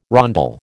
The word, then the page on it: rondel: American English